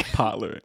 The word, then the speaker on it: Pollard: Shout out to Tiffany Pollard. That's how you say her name, right? – Pollard. – Pollard.